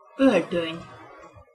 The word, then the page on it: oltony: Hungarian